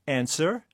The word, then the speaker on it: answer: Or answer. – P. W. – Yeah. W and Neil.